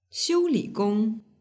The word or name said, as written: xiuligong